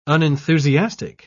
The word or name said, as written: unenthusiastic